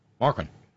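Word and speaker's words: Markwayne: Mark Wayne Mullen, gonna say it that way. Mark Wayne. Markwin. Trying to reduce it to one syllable.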